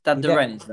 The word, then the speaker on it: Stade de Rennes: 12 to 13, stayed Rennes, is it? I think that's just pronounced on loan. – Stade de Rennes, that. – Yes, Stade de Rennes, yeah.